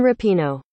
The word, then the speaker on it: Rapinoe: Megan Rapinoe. – That's what they're telling me. They're saying it's Rapinoe.